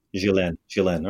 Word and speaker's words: Ghislaine: Am I saying it right, Jen? Ghislaine? Yes? Ghislaine.